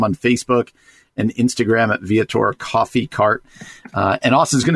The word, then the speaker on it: Viator: He is a worship leader, but also the owner of Viator. Is that, am I saying that correctly? Viator? – We pronounce it Viator Coffee.